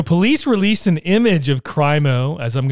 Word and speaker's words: Crimo: His name was Robert Crimo, C-R-I-M-O. Is it Crimo? I don't watch the news, so I don't know how they're pronouncing it. – Crimo.